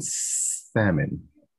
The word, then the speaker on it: salmon: Ooh, I pronounced the L in salmon.